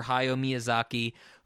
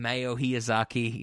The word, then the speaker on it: Hayao Miyazaki: Next week, the preamble of that episode is going to be Ben and I talking about our love for Hayao Miyazaki, who he keeps getting his name wrong and keeps calling him Mayo Hiyazaki.